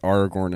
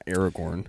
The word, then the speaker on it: Aragorn: If you're listening for the first time, we pronounce it as Aragorn and not Aragorn. – Yeah. – Because that's how Grant told me to pronounce it, and he knows better than me.